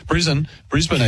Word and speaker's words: Brisbane: Matt in Brisby. Brisbane even.